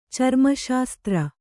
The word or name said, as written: ಚರ್ಮಶಾಸ್ತ್ರ